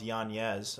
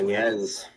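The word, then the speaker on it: Yáñez: I learned that there's an N-Y-E over the N. It's pronounced Yanez. I learned that this Yanez.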